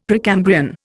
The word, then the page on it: Precambrian: Indian